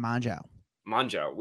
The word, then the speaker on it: Mongeau: Tana Mongeau.